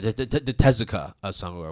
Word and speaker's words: Tezuka Osamu: Whereas this, on the other hand, yes, it does have the Osamu Tezuma Award, right? – Mm-hmm. – Did I pronounce that correctly? – Yeah. – Yeah, the Tezuka Osamu Award.